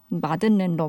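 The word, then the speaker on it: madeleine: 근데 이 발음이 미국 영어로는 메들렌이고 영국 영어로는 마들렌이라고 하네요. 우리는 보통 마들렌이라고 부르죠. 마들렌 러브 듣고 왔습니다.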